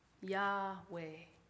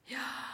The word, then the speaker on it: Yahweh: Yahweh. Try saying the first syllable on the in-breath and the second syllable on the out-breath. Yahweh. Yahweh.